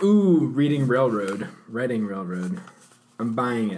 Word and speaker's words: Reading: My dad said it was a place in Pennsylvania called Reading. – Wow. – So yeah, it's pronounced it that way.